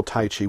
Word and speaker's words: Tai Chi: What most people call Tai Chi, which is actually pronounced Tai Chi, because the word Qi is not in the title for this exercise.